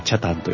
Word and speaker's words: 北谷: イオンの茶炭店で買って食べてみました。 – 北谷と書いて茶炭と読むってやつですね。